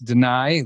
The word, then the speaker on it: Danay: I am pronouncing it right. It's Danay, like what a judge might do is deny.